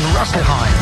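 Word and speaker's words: Rüsselsheim: Harold Hummel in Rosselheim.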